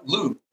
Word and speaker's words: Loupe: It's it's it's a uh, Loupe. It's spelled like Coupe de Ville, just with an L.